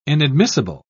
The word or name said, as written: inadmissible